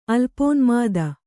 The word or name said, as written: ಅಲ್ಪೋನ್ಮಾದ